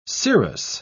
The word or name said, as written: serous